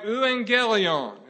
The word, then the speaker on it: euangelion: And it was the word, if you were to say it in Greek or butcher it in Greek like I'm about to do, it would be something like euangelion.